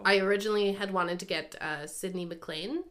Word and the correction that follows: McLennan: to do McLennan. – McLennan. Oh my God, I say her name wrong every time. Sydney McLennan.